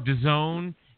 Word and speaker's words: DAZN: You know, and then Eddie Hearn said, no, it's DAZN instead of the zone.